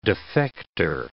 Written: defector

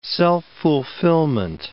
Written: self-fulfillment

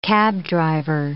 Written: cabdriver

